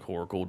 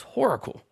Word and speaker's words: Whoracle: And I was always like, is it pronounced Oracle or Horacle? It sounds like Horacle to me. And I think it was Scholes like, no, you mean Oracle? I'm like, no, it's Horacle.